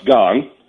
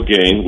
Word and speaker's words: Gane: Yeah, so, by the way, it's pronounced Gong. Okay. It's a Cyril Gong, which John Jones called him Cyril Gain, which is just very funny, guys.